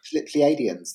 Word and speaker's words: Pleiadians: Is it the Pleiadians or I can't even say it right. – Oh, you're right. Pleiadians. That's it.